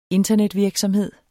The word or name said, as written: internetvirksomhed